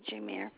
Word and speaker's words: Jameire: Thank you, Jameer.